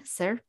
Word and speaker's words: Circe: Circe. – Circe.